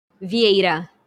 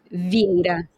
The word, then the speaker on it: Vieira: Vieira. – Vieira. Am I close? – Vieira. You're close. – Give me one more shot. Say it again. – Vieira. – Vieira.